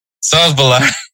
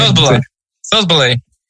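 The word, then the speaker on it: Szoboszlai: But yeah, so congratulations to Robert Lewandowski and Dominic Sosbola. Sosbola. – No, you said it right the first time. Now you just have to wait a little bit, kind of think it in your mind. – Sosbola.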